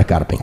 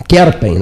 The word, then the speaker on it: Kerpen: lá em Karpen, Kerpen, não é Karpen, Kerpen, na Alemanha, a pronúncia corretíssima é Michael Schumacher.